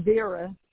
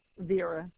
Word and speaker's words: Vera: Oh, Vera. Vera. – How do you spell that? – V as in Victor, E-R-A.